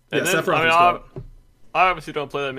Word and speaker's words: Sephiroth: Yeah, Sephiroth